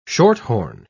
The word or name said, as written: shorthorn